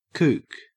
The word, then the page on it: kook: Australian English